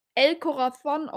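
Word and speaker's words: El Corazón: Äh, ich kann wirklich ein bisschen Spanisch, wusste, weiß keiner, aber es wird wirklich El Corazon ausgesprochen und das heißt das Herz.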